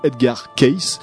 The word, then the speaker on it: Edgar Cayce: ça s'écrit C-A-Y-C-E, Edgar Cayce, et vous verrez que le personnage est encore très vivant dans l'esprit de beaucoup de personnes, même s'il est mort en 1945.